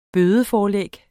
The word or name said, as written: bødeforlæg